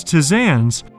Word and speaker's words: tisanes: The word of the day is tisane, spelled T-I-S-A-N-E. Tisane is a noun that refers to aromatic or herb-flavored tea. The plural is tisanes, formed by adding an S, though this S is silent in the original French.